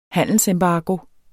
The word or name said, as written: handelsembargo